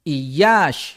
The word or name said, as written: Yáax